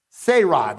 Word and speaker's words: Sarai: Sarai, that's her name. That's how you pronounce it.